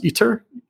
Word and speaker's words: ITER: Or, I mean, it seems like for the case of a fusion reactor, like the biggest project is, I believe it's pronounced ITER, I-T-E-R.